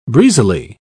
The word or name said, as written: breezily